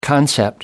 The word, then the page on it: concept: American English